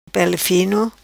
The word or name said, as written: Pele Fino